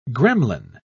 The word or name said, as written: gremlin